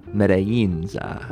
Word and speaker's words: Yinzer: Meta Yinzer. Meta Yinza. – Right. – Meta Yin. – Yes.